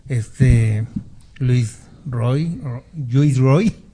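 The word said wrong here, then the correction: Roig: o cómo, se pronuncia en catalán? Roch. Roch.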